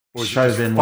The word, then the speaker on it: Chauvin: Was it Chauvin? – Chauvin works for me. Yeah, Chauvin works.